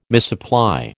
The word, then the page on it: misapply: American English